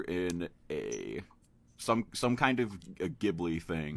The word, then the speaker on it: Ghibli: You don't need to fucking live there to see that way they pronounce it is Ghibli with a soft G.